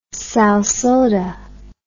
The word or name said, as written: salsoda